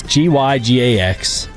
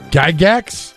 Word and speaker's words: Gygax: It's Gygax. How would you pronounce that? – Say it again. – Gygax. – Gygax? – Exactly.